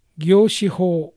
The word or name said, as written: Gyoshiho